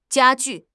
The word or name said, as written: 加剧